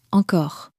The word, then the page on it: encore: French